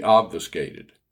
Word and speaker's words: obfuscated: "I will not be obfuscated." First of all, obfuscated is a form of the verb obfuscate. It is spelled O-B-F-U-S-C-A-T-E, and the U is short like a.